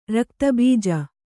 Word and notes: ರಕ್ತಬೀಜ: rakta bīja

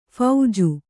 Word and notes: ಫೀಜು: phīju; Kannada

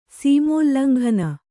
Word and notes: ಸೀಮೋಲ್ಲಂಘನ: sīmōllanghana

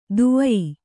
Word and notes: ದುವಯಿ: duvayi

